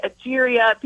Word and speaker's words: Egeria: Well, you mentioned somebody, and I'm not sure if I'm going to say this right, Egeria? – Yes. – Did I say that correctly? – Egeria. People say different things. And her name's actually spelled differently in different books. I say Egeria.